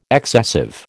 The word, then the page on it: excessive: American English